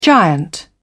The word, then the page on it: giant: British English